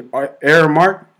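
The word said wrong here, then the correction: Aremac: Is that how you say it? R-Mac, I'm sorry. R-Mac. It's camera spelled backwards.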